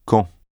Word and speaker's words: quand: The pronunciation of quand. Unlike English, where QU is often pronounced quoi, in French, QU is generally pronounced que, as in que.